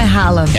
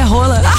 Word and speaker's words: Haaland: E se eu contar pra vocês que a pronúncia do Haaland não é Haaland? – É Haaland. Rala. – Não, é rola.